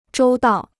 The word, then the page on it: 周到: zhōu dao